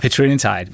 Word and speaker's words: petrelintide: Jamen det, der er helt centralt for os, det er, at vi vil lave petrelintide som et alternativ. – Hvordan udtalte du det? – Petrelintide. – Men okay, det er så, petrelintide.